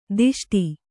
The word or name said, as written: ದಿಷ್ಟಿ